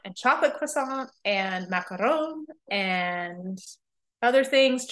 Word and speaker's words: croissant: Um, and then of course just your normal French fare of like baguettes and croissants and oh, I just said it correctly, croissant.